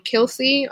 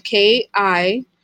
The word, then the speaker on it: Kilsy: And mine is Live Out Loud Kilsy. Live Out Loud is spelled, you know, the regular way. And my name is spelled KILSY.